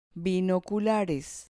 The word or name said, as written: binoculares